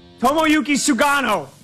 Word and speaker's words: Tomoyuki Sugano: Tomasaki? No. Tomoyuki. Tomoyuki Sugano. Gotta be it. – Nailed it.